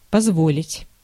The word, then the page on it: permettre: France; /pɛʁ.mɛtʁ/